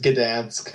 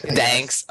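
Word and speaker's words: Gdańsk: Just read it as Gdansk. – Gdansk.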